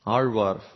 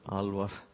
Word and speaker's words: Alvar: Alvar, we say in Tamil, but Alvar, easy to pronounce.